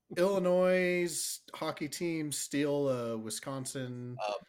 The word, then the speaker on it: Illinois: It's pronounced Illinois. – Illinois. – Yeah, that's right.